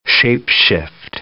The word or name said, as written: shape-shift